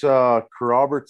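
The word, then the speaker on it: Kerrobert: You're currently playing for, how do you say, Carrobert? – I think we said Carrobert. – Yeah. It's Carrobert, Saskatchewan.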